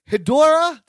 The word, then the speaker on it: Hedorah: We are doing, get this, listener, Godzilla versus Hedorah.